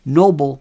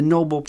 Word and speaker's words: Nobel: There was a piece where Karine Jean-Pierre referred to the Nobel Prize. The Nobel Prize. Not the Nobel Prize, the Nobel. Alfred Nobel.